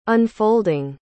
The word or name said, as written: unfolding